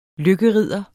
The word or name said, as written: lykkeridder